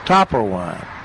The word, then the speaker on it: Topperwein: The interesting thing about it was everybody says Topper Wine. Okay. Even back in the old days, they would say Topper Wine. And so when they would spell it, they put it T-O-P-P-E-R-W-E-I-N.